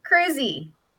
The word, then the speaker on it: Cruzy: Cruzy. Like you're driving. – Cruzy. – Like you're cruising in a car with a long vowel E at the end. Cruz E.